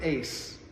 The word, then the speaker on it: eis: In the Greek, it's the word eis. We'd spell it E-I-S, eis.